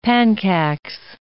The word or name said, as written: panchax